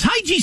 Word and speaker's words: hygge: I have the pronunciation of that word if you would like. – Okay. – Hygge. – Hygge? – Hygge. – Hygge. Okay.